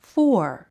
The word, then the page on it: fore: fɔr; フォア